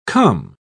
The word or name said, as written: come